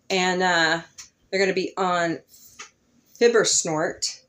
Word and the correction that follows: Fibble Snort: soon. Todd, you're awesome. Shout out. – Fibble Snort. – Fibble Snort. Thank you. I mispronounce everything.